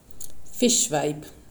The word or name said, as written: Fischweib